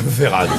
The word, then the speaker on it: Verhaerden: Véradan. – Non, ça s'écrit Verhaerden, mais moi je prononce à la française Véradan.